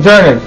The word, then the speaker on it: Journey: Ia até comentar no Journey, acho que é. É isso o nome, certo? Tô pronunciando certo? – Isso, Journey.